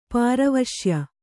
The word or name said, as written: ಪಾರವಶ್ಯ